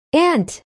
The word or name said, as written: ant